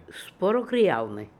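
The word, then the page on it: Disputekrialnian: Russian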